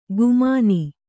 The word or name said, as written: ಗುಮಾನಿ